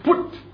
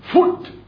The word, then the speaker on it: Put: Kind of hard to pronounce the word Put. Foot. Alright. Sometimes it sounds like a foot. Foot. It's actually what it is.